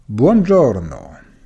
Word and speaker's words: Buongiorno: Und auch hier siehst du, das G von Buongiorno wird wie ein J ausgesprochen, vor einem hellen Vokal, vor einem I oder einem E, J, ansonsten wie ein G.